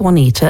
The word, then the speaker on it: Juanita: Well, my name is Juanita and it's spelled J-U-A-N-I-T-A and it's a Spanish name.